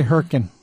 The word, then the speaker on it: Hurricane: I say Hurkin and Laverkin. – Because, well, that's the proper way to pronounce them.